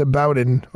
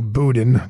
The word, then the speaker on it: Boudin: That San Francisco District Attorney Chesa Boudin, or Boudin, depending on how you want to pronounce that, he said the choice not to seek re-election he's putting his family first.